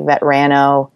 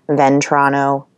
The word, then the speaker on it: Vetrano: So, like, Vetrano, or they'll stick an extra N in there, Ventrano, but nothing that terrible.